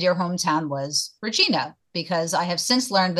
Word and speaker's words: Regina: But that's, you know, we help people through that little problem by saying that, you know, we live in the city that rhymes with fun. They're right. Okay. It's Regina.